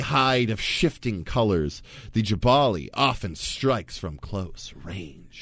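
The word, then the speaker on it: Gbahali: But I believe it's Jabali, and I'm going to stand by that. – It's G-B-A-H-A-L-I.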